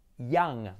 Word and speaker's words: young: Young, ¿vale? Fíjate que la Y no va a ser realmente como una J, como chun, chulai, chaket, sino ya.